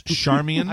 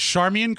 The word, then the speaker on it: Charmian: It's Charmian. – I believe it is Charmian Carr. And Charmian Carr.